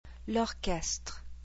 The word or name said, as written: L'orchestre